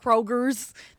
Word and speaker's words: Kroger: My dad be like, Kroger's. Nigga, say ain't no S on no. – It's Kroger. Okay. Stop. – Anyway. – Say it correctly. Yes, girl, it's with one. It's with no S.